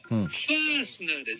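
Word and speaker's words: fastener: そう、実はこの日本人が普通に言葉として使ってカタカナでファスナーって言ってるこれが英語発音だとファースナーで前半が本当はもうちょっと長いんですが、これが正しい呼び名なんですよ。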